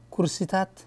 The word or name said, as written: chairs